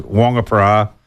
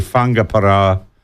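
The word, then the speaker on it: Whangaparāoa: To help Bella with the pronunciation of Whangaparoa, most older locals pronounce it Whangaparoa. When speaking Māori, trying to be woke, they say Whangaparoa. Rolling your tongue on the R.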